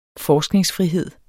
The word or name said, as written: forskningsfrihed